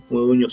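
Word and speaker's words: Modugno: Moduño se pronuncia como Eñe, GN.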